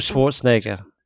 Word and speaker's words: Schwarzenegger: Arnold Schwarzenegger.